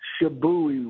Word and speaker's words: Shibui: We wanted to talk about the next song we have up is going to be, now, is it Shibui or Shibu? How do you pronounce that? – Shibui, which in Japanese means restrained elegance.